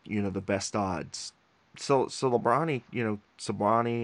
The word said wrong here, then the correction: Celebrini: or whatever you want to, whatever his pronunciation, Celebrini, I think. – Celebrini.